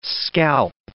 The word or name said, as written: scalp